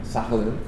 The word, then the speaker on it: Sakhalin: Sakhalin.